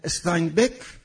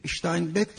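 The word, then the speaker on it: Steinbeck: یک دوره، دوره زمان جنگ جهانی اوله که سردمداران و نویسندگان بزرگ این دوره استاینبک تلفظ آمریکاییشه، اشتاینبک تلفظ آلمانیشه، استاینبک، همینگوی و فاکنر بودن.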